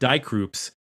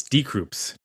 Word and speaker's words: Die Krupps: I just learned that it's not Die Krupps. It's Die Krupps. I've been saying that wrong for 25 years.